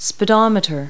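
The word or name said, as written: speedometer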